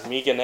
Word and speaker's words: Megan: Okay, we'll move on to Holly Holm and Megan Anderson. Or Megan, I'm not sure how they pronounce it. – Megan. – Sure.